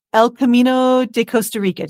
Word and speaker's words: El Camino de Costa Rica: So the El Camino de Costa Rica.